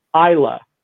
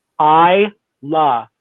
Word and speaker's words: Islay: And if you're going to pronounce it Islay or Islay or whatever, that's not the correct way to do it. So if you want to be in the know, I la is the best way to pronounce that and not cause any offense.